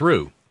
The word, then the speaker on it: roux: What's the right pronunciation? Is it roux or yeah. – roe? – No, it's roux.